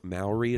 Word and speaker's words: Maori: Maori is how it's pronounced.